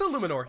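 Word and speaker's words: Eluemunor: I hope I said that right. Illuminor. Every time I say it wrong. – We practiced that before the show, folks. – Yeah, and maybe it's still wrong. – It's Illuminor. You're right.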